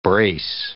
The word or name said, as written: brace